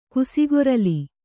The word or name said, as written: ಕುಸಿಗೊರಲಿ